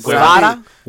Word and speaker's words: Guevara: Guevara? – Sammy Guevara.